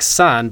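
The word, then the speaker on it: san (三): The huge sun is shining behind the tree.Three in Chinese sounds a bit like san, but it has the vowel sound ah.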